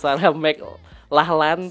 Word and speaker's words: Sarah McLachlan: By the way, ini teman-teman yang gak tahu ya, cara bacanya Sarah McLachlan, tulisannya McLachlan nih ya. Jadi Sarah McLachlan ya.